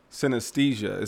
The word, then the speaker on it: synesthesia: I want to pause you real quick on talk about, because you spoke on synesthesia. Is that how you say it? – Yep, that's right.